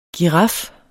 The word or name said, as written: giraf